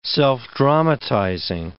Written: self-dramatizing

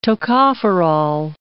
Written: tocopherol

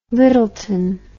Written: lyttleton

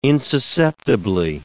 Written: insusceptibly

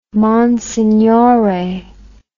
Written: monsignore

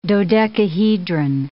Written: dodecahedron

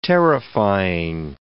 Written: terrifying

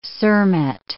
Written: cermet

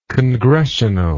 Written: congressionally

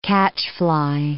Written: catchfly

